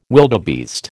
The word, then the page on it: wildebeest: American English